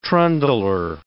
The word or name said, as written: trundler